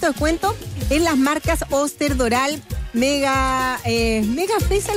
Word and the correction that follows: Magefesa: ¿lo dije bien? – Mega Fesa. – Ah, de Magefesa.